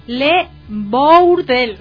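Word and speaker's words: Le Bourdel: Les voy a más o menos a pronunciar cómo se escribe Le Burdel. Le Burdel. B-O-U-R-D-E-L.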